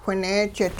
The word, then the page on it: whineejit: Hupa